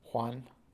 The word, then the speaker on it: Juan: Juan, J-U-A-N, Oyervides, O-Y-E-R-V-I-D-E-S.